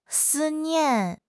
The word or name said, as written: 思念